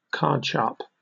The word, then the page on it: cardsharp: British English